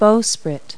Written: bowsprit

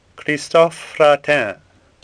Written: Fratin

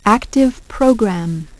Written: active program

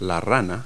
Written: frog

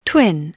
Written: twin

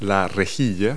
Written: grille

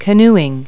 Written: canoeing

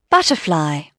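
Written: butterfly

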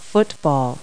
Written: football

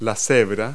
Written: zebra

